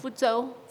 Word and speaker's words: Fuzhou: Fuzhou.